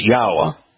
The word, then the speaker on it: Yahweh: All you have to do is look at the Hebrew word Torah or the verb Haya, combine those two, and you know how to pronounce his name. It's Yahweh.